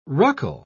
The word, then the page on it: ruckle: British English